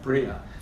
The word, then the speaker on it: Bria: Bria.